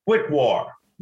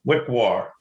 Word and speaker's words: Wickwar: Wickwar. Wickwar. Say W-I-C-K-W-A-R. Wickwar.